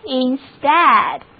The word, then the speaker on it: instead: 好了，EA 字母组合读作 a，单词就读作 instead，重音是放在第二个音节上的。